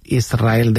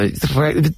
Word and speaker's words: Israel: Israel, ¿no? – Y la S con la R es difícil de pronunciar. La gente dice Israel. Sin que suene la S, Israel, sí, es difícil pronunciarse.